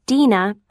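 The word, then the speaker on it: Dena: S-T-E-V-E-N. – 2. – Hi. My name is Dina Brown. – Hello. How do you spell your first name? – It's D-E-N-A.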